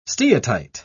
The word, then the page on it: steatite: British English